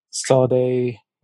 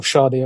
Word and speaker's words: Sade: So I think for me, like Shola Ama, Sade, or Sade, I should say.